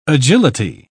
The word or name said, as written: agility